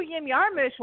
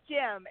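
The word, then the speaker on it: Jim Jarmusch: Everyone says Jim. It's Yim. It's Yim Yarmush.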